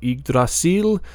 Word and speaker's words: Yggdrasil: It's spelled YGG, so I'm pronouncing it Yig, because that's kind of how you would pronounce the first syllable of Yggdrasil, the old tree of life.